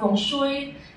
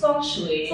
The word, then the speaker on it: feng shui: And that is the correct pronunciation. It's not feng shui. It's feng shui.